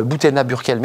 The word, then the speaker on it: Boutayna Burkel: Euh, Boutena Burkel, merci d'être avec nous. Vous êtes euh, dirigeante de The Helper. – Exactement. – J'ai bien prononcé Très bien, parfait.